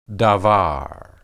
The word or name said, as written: dabar